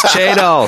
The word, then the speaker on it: chadol: Chador. Chador. It's Chadol. Say it right.